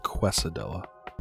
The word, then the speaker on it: quesadilla: It's pronounced quesadilla.